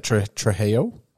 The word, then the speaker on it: Trejo: Isn't it Trejo? Isn't that how you're supposed to say his name? – I could be a mispronunciation. – He's the Mexican dude. – Yeah. – He's on Guns of Anarchy. And yeah, you've got it right.